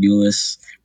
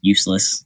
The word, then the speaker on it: Euless: Yeah, a little small town called Euless, right smack dab between Dallas and Fort Worth. Everybody calls us useless because that's how everyone pronounces it when they see it.